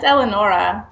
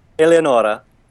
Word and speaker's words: Eleonora: It's Eleonora. – Okay. Oh, okay. – Eleonora. – Yeah. – Oh, Eleonora. Okay. – Oh, my God. You said that beautifully. Say it again. – Eleonora.